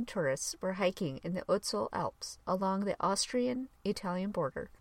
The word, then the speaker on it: Ötzi: I always pronounced it Ötzi, but I found out that it's actually pronounced more like Ötzi.